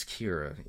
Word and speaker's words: Cyra: Her name is spelled like C-Y-R-A. – I think it's Kira. – I think it's Kira, yeah.